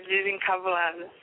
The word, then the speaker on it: Desencambulada: I want to play Desencambulada. – Yeah. – If I say it correctly. – Yeah, Desencambulada.